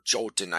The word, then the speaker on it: Jotun: But Jotunheim is land of the Jotun, or Jotun, I've also heard it pronounced.